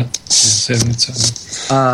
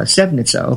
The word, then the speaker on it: Sevnica: And then she lived, and again, I don't know how to pronounce this, in another town also 20 miles away from you called Sevnica. – Yeah, Sevnica. – Sevnica, okay, thank you for helping me with that.